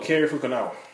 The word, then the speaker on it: Cary Fukunaga: Oh, Cary Fukunaga.